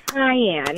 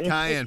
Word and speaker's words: cayenne: Yes, the word that I actually pronounced wrong was Kanye pepper. Oh. Or, yes, I'm sorry, cayenne. – Cayenne pepper. – Cayenne. Pepper. – Uh-huh. I called it Kanye.